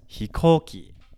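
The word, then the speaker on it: hikoki (飛行機): If I want to say something along the lines of hikoki, hikoki would be pronounced, hikoki, hikoki. So we're going to go up slightly.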